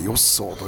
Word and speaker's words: 吉宗: これは吉宗、徳川吉宗の吉宗と書いてよっそーと読むんですけれども、